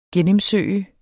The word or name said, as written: gennemsøge